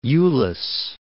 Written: Euless

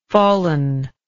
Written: fallenness

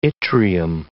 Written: yttrium

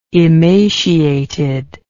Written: emaciated

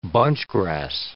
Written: bunchgrass

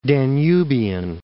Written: Danubian

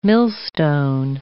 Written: millstone

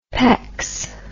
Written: pecks

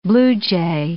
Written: blue jay

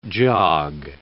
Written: jog

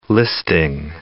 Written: listing

